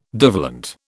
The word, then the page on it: divalent: British English